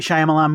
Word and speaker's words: Shyamalan: Shyamalan? Yeah, I think so. This is the new M. Night Shyamalan movie.